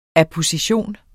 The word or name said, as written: apposition